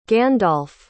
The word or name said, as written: gandalf